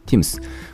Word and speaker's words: thymus: で、その、えー、英語で、えー、これ SIMS、ちょっと発音難しいですけど、 THYMUS と書いて TIMS。